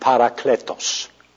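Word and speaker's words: Parakletos (παράκλητος): Or in the Greek, it's pronounced Parakletos. Made up of two words, P-A-R-A, para, and then the second word is kaleo, K-A-L-E-O, parakaleo.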